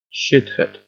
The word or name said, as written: shithead